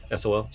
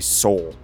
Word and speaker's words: sol: A day on Mars is referred to as a sol. This is so it's like you pronounce like S-O-U-L.